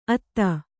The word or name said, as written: ಅತ್ತೆ